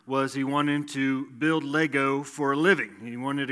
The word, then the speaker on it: Lego: And I don't know if you knew this, but you don't pronounce it Legos. It's Lego, even if it's plural, and I learned that, I guess, this last year or two.